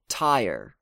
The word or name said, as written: Tyre